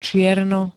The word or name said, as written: čierno